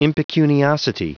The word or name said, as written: impecuniosity